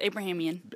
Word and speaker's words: Abrahamian: Yes, Abrahamian.